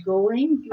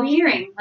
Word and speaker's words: Göring: Hermann Göring, G-O-E-R-I-N-G. – Göring. – Göring. – Oh, Göring.